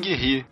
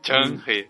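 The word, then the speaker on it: Zheng He: É, é, eu lembro que desde o pré-vestibular eu falava zeng Zenghi. – Só que agora é, eu tô. Como a gente fal... lê, né? É, é, exatamente. Eu nunca tinha visto ninguém falar a pronúncia correta em. É Chang-He.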